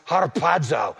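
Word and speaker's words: harpazo (ἁρπάζω): It's an A with a breathing mark, and it's pronounced harpazo, or various forms of that.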